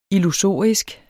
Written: illusorisk

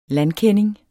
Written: landkending

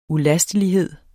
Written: ulastelighed

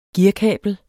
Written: gearkabel

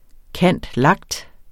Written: cand.lact.

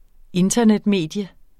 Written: INTERNETMEDIUM

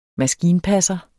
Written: maskinpasser

